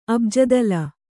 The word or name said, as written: ಅಬ್ಜದಲ